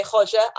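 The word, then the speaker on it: Roger: It's bad because I speak Portuguese, so I really should say Hodja. I know. Is it Hodja? Bad. It's Hodja. Yeah, it's Hodja. – Okay. – Because obviously you pronounce a H because of the Brazilian-y bit.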